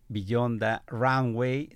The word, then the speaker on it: Beyond the Runway: Lía Rendón es directora de operaciones estratégicas del sindicato mexicano GIACI para hablarnos de Beyond the Runway. ¿Sí? ¿Está bien pronunciado? – Es correcto, Beyond Runway.